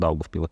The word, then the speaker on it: Daugavpili: Nerunājot jau par, nerunājot jau par Daugavpili.